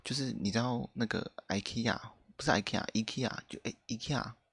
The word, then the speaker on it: IKEA: 就是东南亚某一个国家的 IKEA 的官方的。脸书还是什么社群平台，就是教大家怎么念，是要念 IKEA 的样子，所以我就念 IKEA，就现在就目前改念 IKEA，我觉得蛮合理的啊。